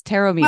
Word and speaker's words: Taormino: I've said it wrong. I say Tristan Taormino. It's Taormino. – Well, it's Taormino, but Taormino is way closer than a lot of people get.